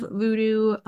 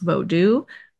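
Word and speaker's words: vodou: But yeah, generally what we learned kind of about the history of voodoo and that it is pronounced vodu by approach practitioners. And voodoo is kind of a Hollywoodization of it.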